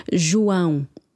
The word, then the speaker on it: João: and that's how we pronounce John in Portuguese. João.